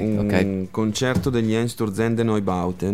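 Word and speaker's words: Einstürzende Neubauten: No, non è corretta. La pronuncia corretta sarebbe Einsturzende Neubauten.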